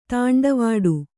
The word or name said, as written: ತೊಂಡುವಾಡೆ